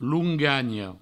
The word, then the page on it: longanha: provençal; loungagno